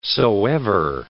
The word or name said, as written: soever